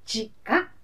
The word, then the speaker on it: 和: 地下という字は平和の和と書きまして、地下と読みます。地下が生まれたのは今から 165 年前の安政 5 年。父親は現在の栃木県那須黒羽藩のご家老。地下は幼い頃から体操賢く、その上美人。